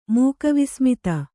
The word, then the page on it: ಮೂಕವಿಸ್ಮಿತೆ: mūkavismite; kannada